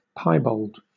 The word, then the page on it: piebald: British English